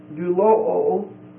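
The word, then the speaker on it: doulo (δουλόω): And then verse 18, it's the verb form, doulo, D-O-U-L-O-O. The last O is a long O.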